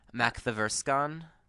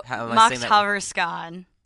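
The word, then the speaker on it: Makthaverskan: Mack the Verskan. – Oh, Mack Haverskan. – Oh, I wasn't even close. – Yeah, well, it's spelled like you just break it up. Like, Macht is Swedish for power.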